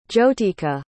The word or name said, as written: jyotika